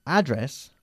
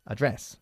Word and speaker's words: address: Bien, address es como lo pronuncian los norteamericanos. Mm-hmm. Los británicos lo pronunciamos address. – All right. – Así que tú eliges.